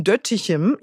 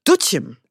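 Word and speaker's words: Doetinchem: Dieses Jahr ist es in, also ich hätte es ausgesprochen, Döttichem, ja, würde mich wahrscheinlich jeder irgendwie schlagen, jeder Niederländer, aber es wird ausgesprochen Döttichem. Also in Döttichem und es ist jedes Jahr am anderen Ort, also beziehungsweise wo das Königspaar hinkommt ne, und sich zeigt.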